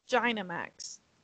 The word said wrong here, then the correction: Gigantamax: Gentamax. – You'll say it correctly soon. I have faith in you. – Gigantamax.